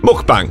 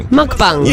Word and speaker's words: mukbang: Mukbang. – Mukbang.